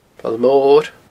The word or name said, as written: Poll Mor